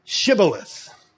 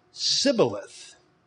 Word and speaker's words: Shibboleth: And if he said, nay, then they said unto him, I'm paraphrasing here now, okay, say Shibboleth. It's a test. And he say Sibboleth, for he could not frame to pronounce it right.